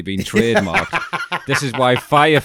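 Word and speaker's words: Fyre: Is it FYR? F-Y-R, R-E. Are we saying this? Is it correct? Are we pronouncing it right? – Oh, Firefest. No. Oh, right. So it turns out it was fire all along.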